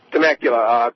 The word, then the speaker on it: Temecula: And in Temecula, California, if I'm pronouncing that right, you're live with Bernie Sanders. – Temecula.